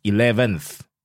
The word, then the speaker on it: 11th: Okay, you pronounced the TH, 11th.